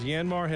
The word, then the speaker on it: Yanmar: Yanmar has bite, and you've wondered how to pronounce that construction equipment. Now, it's Yanmar, and Yanmar mini excavators.